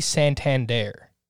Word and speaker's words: Santander: Is it pronounced like the bank? – I've listened to Oreo's broadcasts, and I've wondered this before too, and they say Santander.